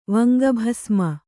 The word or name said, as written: ವಂಗಭಸ್ಮ